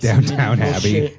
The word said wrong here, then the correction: Downton: How did I say it? How do you say it? – Downton. Downton Abbey.